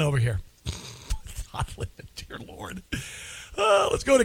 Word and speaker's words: Don Lemon: What are your thoughts on Don Lemon, who rhymes with women, by the way?